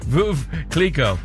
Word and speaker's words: Veuve Clicquot: Veuve Clicquot. – Veuve. – Jeez. – Do you know how to say it? – Yeah, I know how to order it, too. – Okay, good. Veuve Clicquot.